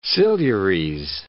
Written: Silures

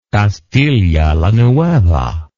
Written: Castilla la Nueva

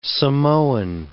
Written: Samoan

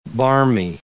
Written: barmy

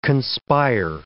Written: conspire

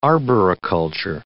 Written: arboriculture

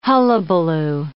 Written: hullabaloo

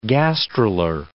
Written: gastrular

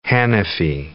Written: Hanafi

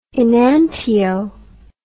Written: enantio

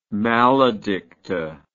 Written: maledicta

